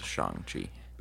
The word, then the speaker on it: Shang-Chi: It is pronounced Shang-Chi. – It's called Shang-Chi. – It's Shang-Chi. – Shang-Chi. – Nope, it's Shang-Chi. – How do you know? – They literally say it in the trailer.